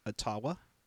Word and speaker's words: Ottawa: But my question is, I think you said it wrong. Isn't it Ottawa? – Oh, that's right. Ottawa.